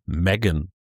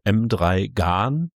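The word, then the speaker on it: M3GAN: Und zwar habe ich mich auf den Film Megan gestürzt. Eigentlich, wenn ich es vorlesen würde, wäre es M3 Gahn, weil so schreibt man das nun mal.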